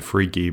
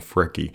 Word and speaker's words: Freki: I like to call them Jerry and Freaky, by the way, just to entertain myself as a terrible pronunciation that sounds funnier in the modern era, Jerry and Freaky, but I think it's more correctly pronounced Geri and Freki.